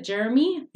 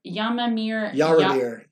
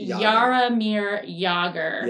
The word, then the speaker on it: Jaromir Jagr: And so correct me if I have the name wrong. Is it Jeremy? – Jaromir Jagr. – Jaromir Jagr.